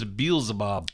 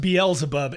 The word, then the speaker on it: Beelzebub: I thought she was Beelzebub. – It's pronounced Beelzebub.